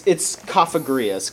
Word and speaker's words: Cofagrius: It's Cofagrius, because it's supposed to be like coffin.